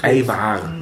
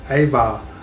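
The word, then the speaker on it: Ajvar: Eiwar.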